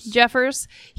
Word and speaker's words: Jeffress: And Jeffress, how do you say his name? – Jeffers.